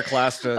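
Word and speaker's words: Clastres: They don't really engage with anarchism in any real way, except through, I'm going to say this wrong, so Cluster's work, is that how you say it? – Pierre Cluster, Yeah.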